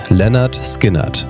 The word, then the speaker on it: Lynyrd Skynyrd: Ausgesprochen wird der Name schlicht: Lynyrd Skynyrd.